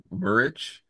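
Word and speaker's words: Muric: Yeah, Ipswich's keeper, I think his name is pronounced Murich.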